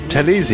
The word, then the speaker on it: Talese: Talese.